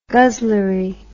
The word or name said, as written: guzzlery